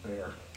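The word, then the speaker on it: bier: And the correct pronunciation is bear.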